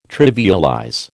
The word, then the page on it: trivialize: American English